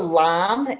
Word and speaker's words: lame: This is, it's called a lam. It's spelled like lame, but it's called a lam.